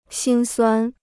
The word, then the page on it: 辛酸: xīn suān